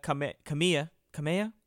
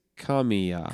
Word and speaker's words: Kamiya: I don't know exactly what it is, but yeah, Hideki Kamiya. How do you say it? – I think it's Kamiya. – Kamiya. That's right.